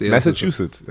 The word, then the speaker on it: Massachusetts: Die Tatsache, dass Cam Newton ja den Bundesstaat, und ich kann immer diesen Bundesstaat, Massachusetts, oh, ich habe ihn noch ausgegrüßt. – Massachusetts. Ja, ja. – Habe ich richtig ausgesprochen.